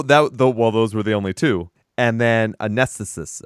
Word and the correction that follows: Anesthetist: I think is how you say it. Anesthesis. – Oh, Anesthetist. – Anesthetist. There you go.